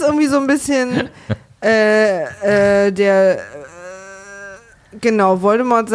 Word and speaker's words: Voldemort: Voldemort. – Voldemort. – Da lassen das T so hart weg, dass mich das hier... The T is silent.